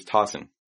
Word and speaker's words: Tossin: Yes, this is Tossin. – Could you spell that, please? – Sure. It's T-O-S-S-I-N.